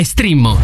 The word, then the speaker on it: Streammo: Entra adesso nel mondo di Strimmo, vai su strimmo.it, si scrive Streammo o scarica l'app.